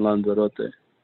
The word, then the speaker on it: Lanzarote: So, and then you end up at I'm not going to be able to say it right, Lazarante? – Yeah. Yeah. – So how long were you there with them for? – Yeah, I played there these past two seasons. I played in Lanzarote.